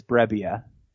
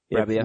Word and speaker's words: Brebbia: So, by the way, it does look like it's Brebbia. – Brebbia. – According to Baseball Reference pronunciation guide.